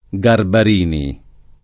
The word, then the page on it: Garbarini: lingua italiana